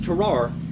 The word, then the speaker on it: Tarrare: Now, his name is pronounced differently in French, but I am not French, so it will be pronounced Tarrare in American.